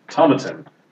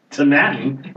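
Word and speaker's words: Tomatin: Established in 1897, Tomaten, to rhyme with satin. Tomaten, rhyme with satin, is also one of the highest distilleries in Scotland.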